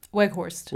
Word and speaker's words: Weghorst: Weghorst.